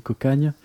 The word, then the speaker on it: Cocagna: Et à ne pas confondre avec l'autre groupe Occitanis, Cocagna, qui s'écrit Cocagna, mais qui se dit Cocagne.